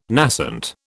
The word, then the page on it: nascent: British English